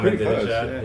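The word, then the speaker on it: Ahmadinejad: I would say LeBron it would have to be Aminadjad. Is that how you say his name? – Yeah, Aminadjad.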